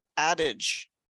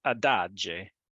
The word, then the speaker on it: Adige: A 26-year-old jogger in the northeastern region of Trentino Alto Adige, Andrea Papis. – I think it's pronounced Adage.